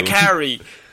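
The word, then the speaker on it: Makkari: You also see Lauren Ridloff's character, Macari, is that how you pronounce it? – Macari, I always pronounce it. I've only ever seen it written down. – Macari.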